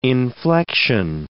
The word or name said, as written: inflection